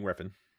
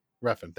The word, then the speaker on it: Refn: I don't know how to say his last name. Nicholas Winding Refn. – Refn, thank you.